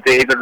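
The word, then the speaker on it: Cawid: I don't know if pronouncing my name is going to help anyone spell it, but you can refer to me as David Rahul.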